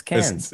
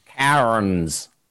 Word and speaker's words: Cairns: Is the place is spelled C-A-I-R-N-S, but Australians refuse to use the letter R when saying the word Cairns. for it's some cans. – It's cans. – It's not. – Cans. Cans. – You're saying cans. You're saying literally cans.